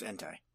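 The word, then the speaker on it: Entei: I might, I might be saying it wrong, but I'm pretty sure it's Entai. – Oh, Entai. No, that sounds better. It sounds more Japanese.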